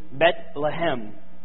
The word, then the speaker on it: Bethlehem: There's a bit of irony here, because Elimelech and Naomi have left Bethlehem, or as it's pronounced in Hebrew, Bethlehem, the house of bread, in order to go and search for bread in Moab.